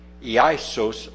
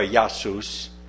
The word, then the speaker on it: Iesous: The Greek, aiosos, which is pronounced iaisos or iasus, Jesus, is spelled in English letters I-E-S-O-U-S.